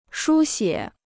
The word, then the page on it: 书写: shū xiě